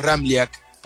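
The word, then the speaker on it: Ramljak: Es un poco complicado porque tiene tres consonantes juntas y la J se pronuncia como una I. Bien pronunciado, el apellido es Ramliac.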